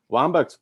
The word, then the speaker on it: Wambach: Wambach's played forever. – And her name rhymes with Bombach, so take that how you will.